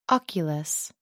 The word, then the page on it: oculus: American English